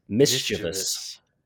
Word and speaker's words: mischievous: It should be mischievous, but it's not. It is mischievous. Mischievous. – I say it like everybody else says it though. – You said mischievous. – Yeah, you had the first syllable and the last syllable correct, but your middle one was a little off.